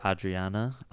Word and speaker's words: Adriana: Short for Adriana. And I'm saying it wrong. She's from Ecuador. So it's like the R is rolled and the D is like a the sound. So and then same with Audrey. It's like A-D-R-I.